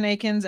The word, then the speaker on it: Eakins: Uh, Twitter, I am Jen Eakins NFL.